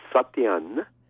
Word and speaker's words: Satyen: Okay, it's pronounced Satyen. – Satyen. – Okay, Satyen, and it's spelled S-A-T-Y-E-N.